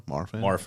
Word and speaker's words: Marfan: Is it Marfan syndrome? You ever hear of that? – Marfan, yeah. – Am I saying it wrong? Marfan. – Yeah.